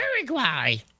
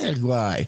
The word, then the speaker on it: Uruguay: So, you know, Derek Gay, who lives in Uruguay, as Homer Simpson calls it, said that to correctly pronounce Uruguay, you have to pretend you're being punched in the stomach. Like, Uruguay.